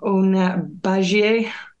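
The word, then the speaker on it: Batlle: Emma, you'll slate my pronunciation again. Ona Bagie. Better. – Beautiful. Well done.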